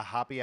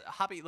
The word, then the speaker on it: happi: And it's hoppy, like H-A-P-P-I. It appears to be like traditional Japanese clothing. I don't know if we're pronouncing it correctly, but we're not saying happy, if that is right.